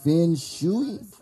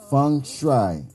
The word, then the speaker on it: Feng Shui: And am I saying it right? Feng Shui? Correct. – I'm trying. – Okay. Say it one more time. – Feng Shui. – Feng Shui.